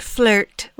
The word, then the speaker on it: Fleurt: It is a shop here in West Seattle called Fleurt Collective and it's spelled F L E U R T, like fleur, like the French word for flower. Uh, but flirt, which I think is fun,